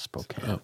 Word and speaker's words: Spokane: Which is Spokane. It's pronounced Spokane.